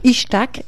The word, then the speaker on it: Itzhak: Je ne sais pas si c'est comme ça que ça se prononce. – Ishtak, j'ai vérifié. – Ishtak Katzenelson. Ouais.